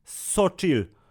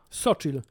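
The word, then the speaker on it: Xochitl: Ja, prze, ja, ja, ja sprawdziłem, musiałem zobaczyć, bo to było element, Ksioktil. Który... Nie, to się czyta... To jest teraz autentyk. Znaczy, nie wiem, czy dokładnie tak, ale y, y, e, e, jej y, wymowa jej imienia które jest imieniem azteckim czy majańskim jakimś tam, w każdym razie wiesz, z dżungli Ameryki Południowej, Sochil. Sochil. Więc so chill.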